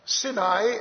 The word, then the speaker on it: Sinai: Sinai, actually, is the pronunciation, and that is also where some of these Sinites had settled.